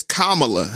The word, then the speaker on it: Kamala: No, it's it's Kamala. – Kamala.